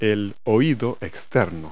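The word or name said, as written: external ear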